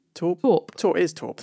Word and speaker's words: taupe: Interestingly, there are some companies now that are developing, how do you pronounce it, T-A-U-P-E? Torp, thank you.